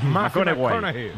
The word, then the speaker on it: McConaughey: ¿Matthew McConaughey? – Sí. – Hay que ir corrigiendo la pronunciación. – McConaughey. Bueno, pues el Matthew McConaughey.